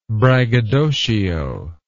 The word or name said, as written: braggadocian